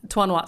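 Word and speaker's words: Trois Noix: Oh, Tuanua. Sorry. It means three nuts in French. – Nuts, okay. – T-R-O-I-S. – Okay. – N-O-I-X. – Beautiful. – And if you imagine it like T-W-A-N-W-A, so Tuanua.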